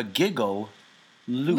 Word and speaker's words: giggle loop: That is spelled the giggle loop. – Loop. – You kind of say it like one word.